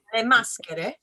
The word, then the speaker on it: Le Maschere: But the branch of the program, the Italian program, is, I might mispronounce it. It's called La Maschere. Is that what it is? – Le Maschere.